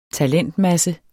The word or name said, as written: TALENTMASSE